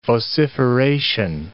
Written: vociferation

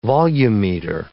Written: volumeter

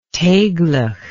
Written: taiglach

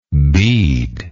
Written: beadlike

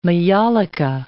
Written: maiolica

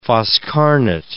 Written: foscarnet

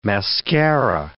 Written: mascara

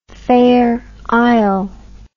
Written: fair isle

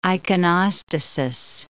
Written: iconostasis

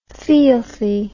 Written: feelthy

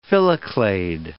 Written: phylloclade

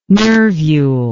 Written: nervule

